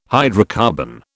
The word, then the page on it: hydrocarbon: British English